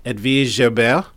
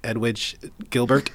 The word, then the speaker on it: Edwige Gilbert: And he started it with a young lady named Edwige Gilbert, if you want to pronounce it correctly. Otherwise, it looks like Edwige Gilbert. However, she is French, and a very gorgeous French.